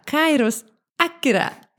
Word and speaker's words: Kairos Akira: Nu ska jag säga välkomna Kairos Akira. – Hallå, hallå. Hej! Sa jag rätt? ja, du sa rätt.